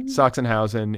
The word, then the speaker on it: Sachsenhausen: And then there's this whole backstory about how the cows are from, forgive the pronunciation, is it Sachsenhausen? – Sachsenhausen